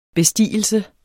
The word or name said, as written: bestigelse